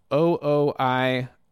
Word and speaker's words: OOIOO: This is a band called O-O-I-O-O. That's how you say it. Two O's and I and two more O's.